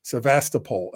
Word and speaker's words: Sevastopol: They pronounce it the different way than we do. We say Sevastopol.